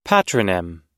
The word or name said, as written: patronym